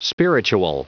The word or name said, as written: spiritual